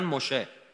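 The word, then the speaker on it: مشه (משה): عبری ها سه و شه تلفظ میکنن عمدتا موسی رو میگن مشه سلام رو میگن شالوم سلیمان میگن شلمان نسا زنا رو میگن نشا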